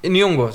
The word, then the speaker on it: Nyong'o: Nyong'o, så, precis.